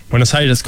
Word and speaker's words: Buenos Aires: Buenos Aires, correct.